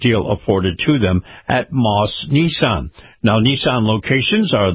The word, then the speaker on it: Maus: Moss is spelled M-A-U-S, and in particular, the Moss Nissan dealerships.